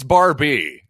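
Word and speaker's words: Barbee: And well, you might recognize the coach for Central Michigan if you have a really good memory, Tony Barbary, who you might remember Tony Barbie. I believe it's Barbie, as I struggle to pronounce one of the classic Mattel toy names of all time.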